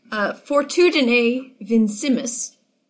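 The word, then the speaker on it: Fortitudine vincimus: Fortudine vincimus. – Did I pronounce that correctly? First question. It's towards the middle of the page. – Yep, generally, yeah. It depends on who you ask.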